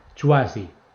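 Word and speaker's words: Chwazi: Se llama, atención a la pronunciación, Chuasi. C-H-W-A-Z-I. C-H-W-A-Z-I.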